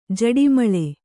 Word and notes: ಝಾಡುಮಾಲಿ: jhāḍumāli

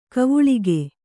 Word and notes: ಗವಳಿಗ: gavaḷiga

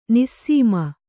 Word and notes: ನಿಸ್ಸೀಮ: nissīma

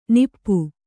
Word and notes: ನಿಪ್ಪು: nippu

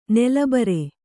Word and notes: ನೆಲಬರೆ: nala bare

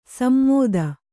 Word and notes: ಸಮ್ಮೋದ: sammōda

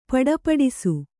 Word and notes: ಪುಟುಪುಟಿಸು: puṭupu'isu

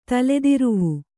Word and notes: ತಲೆದಿರುವು: Kannada

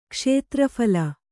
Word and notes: ಕ್ಷೇತ್ರಫಲ: kṣētra phala

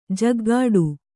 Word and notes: ಝಾಗಟಿ: kannada

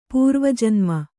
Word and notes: ಪೂರ್ವಜನ್ಮ: Kannada

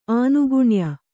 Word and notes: ಆನುಗುಣ್ಯ: ānuguṇya

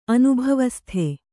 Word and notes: ಅನುಭವಸ್ಥೆ: anubhavasthe

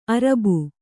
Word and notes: ಅರಬು: Kannada

